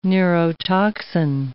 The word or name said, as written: neurotoxin